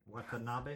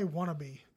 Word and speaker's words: Watanabe: Watanabe? – I always want to say wannabe. Every time I say his name.